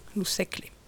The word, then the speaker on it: segle: Donc, attention, ça s'écrit S-E-G-L-E. Et bien, en fait, en occitan, ce groupe G-L, eh bien, il ne se prononce pas gle, il se prononce cle. – Loussecle.